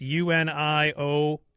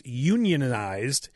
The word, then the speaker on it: unionized: Ask them to pronounce unionized. That's UNIONIZED. That's the joke. And it says a more labor-conscious plumber would pronounce it as unionized. A chemist would pronounce it unionized. Because of ionization.